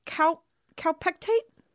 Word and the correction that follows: Kaopectate: Kaopectate. Yeah.